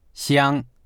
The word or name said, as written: xiang1